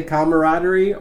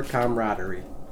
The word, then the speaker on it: camaraderie: So, we will accept if you say camaraderie or camaraderie.